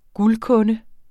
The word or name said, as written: guldkunde